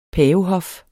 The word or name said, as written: pavehof